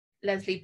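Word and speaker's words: Lesley: They always end it with an I-E, which is for a boy. Leslie boy, but I'm a Leslie girl, so it's EY.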